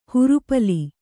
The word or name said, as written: ಹುರುಪಲಿ